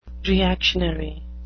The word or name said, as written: reactionary